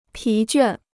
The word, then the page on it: 疲倦: pí juàn